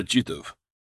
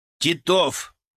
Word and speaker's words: Титов: — Проходи сюда, Титов. – — Титов! — поправил самую большую шишку в интернате я. Ударение на второй слог.